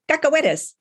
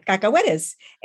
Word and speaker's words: cacahuetes: And she pointed and she goes, cacahuetes. I'm like, ¿qué? And she repeated it, cacahuetes. And we went over this 2 or 3 times and she finally said it slow and I'm trying to repeat it. And I finally got it right.